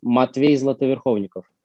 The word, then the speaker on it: Matvey Zlatoverkhovnikov: Well, actually, my name in Russian, as it's supposed to be, sounds Matvey Zlatoverkhovnikov.